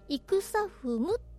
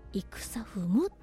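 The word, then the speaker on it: 戦雲: まあ、ドキュメンタリー映画ですけれども、イクサフムという作品で、戦う雲と書いてイクサフムと読みます。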